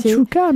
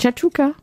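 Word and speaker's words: chakchouka: La fameuse chakouchka, je ne sais jamais le dire, c'est très bon, mais difficile à prononcer. – Chachouka, non? – Oui, oui. – C'est chachouka? – Oui, moi j'ai... – Non, c'est ça, chachouka.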